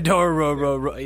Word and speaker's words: Dororo: But I'll say that I'm eyeing Dorohoro. Dorohoro? Is that how you say it? Yeah, it's like Dorohoro or something like that, yeah. – Yeah, Dororo.